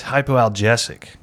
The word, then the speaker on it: hypoalgesic: I'm realizing now that I'm breaking this word down. I pronounced it wrong. – Oh, okay. – It's hypoalgesic.